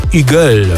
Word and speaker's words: HUGEL: To będzie francuski DJ i producent Hugel. Takiego pseudonim się wymawia. Pisze się normalnie Hugel przez W otwarte.